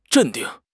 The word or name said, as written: 镇定